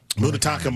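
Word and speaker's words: Munetaka: How do I say his first name? Murakami, I know. – Mutataka Murakami. – That was really good.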